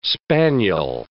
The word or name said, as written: spaniel